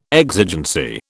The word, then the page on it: exigency: British English